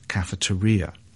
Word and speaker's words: cafeteria: though for many years the word was often pronounced in the Spanish style, cafeteria.